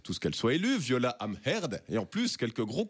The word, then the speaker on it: Amherd: Viola Amherd. Attention, prononcez bien le H de son nom de famille, sinon ça donne Amherd.